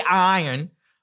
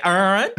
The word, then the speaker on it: iron: I say iron, but, you know, I have family members who say urn.